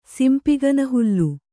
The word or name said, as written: ಸಿಂಪಿಗನಹುಲ್ಲು